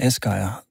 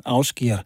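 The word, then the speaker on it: Ásgeir: Afskjert er, som jeg kom til at kalde Asgeir, da jeg først lærte ham at kende. Men siden har jeg mødt en islænding, som siger, at man skal altså udtale hans navn Afskjert.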